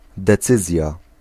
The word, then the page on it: besluit: /bəˈslœy̯t/